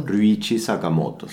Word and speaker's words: Ryuichi Sakamoto: Y también otra música imprescindible es la banda sonora de la película Recuerdos de mi hijo, de Ryuichi Sakamoto, ¿está bien pronunciado? – Ryuichi Sakamoto, sí.